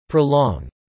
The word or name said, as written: prolong